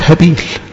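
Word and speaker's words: هبيل (הבל): كلمة باطل في العبري تنطق هبيل، هبيل باطل، باطل بخار زائل مؤقت، هباء خواء بلا معنى، كل دوت ماشي في كلمة هبيل، ماشي في كلمة باطل.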